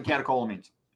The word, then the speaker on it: catecholamines: but I think it's catecholamines. Don't know if I said that right. – Yeah, I usually pronounce it catecholamines.